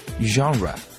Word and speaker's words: genre: 啊、uh, But here the here's a word is very important and the pronunciation is a bit tricky. It's a French word. You can say genre. Okay, let me spell it. Uh, genre, G E N R E, genre.